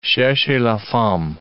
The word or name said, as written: cherchez la femme